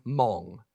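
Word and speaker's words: mong (忙): It's pronounced in Cantonese, mong.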